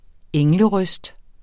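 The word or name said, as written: ENGLERØSTER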